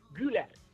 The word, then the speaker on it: Güler: ¿Cómo se pronuncia? – Nosotros pronunciamos como Güler, Arda Güler.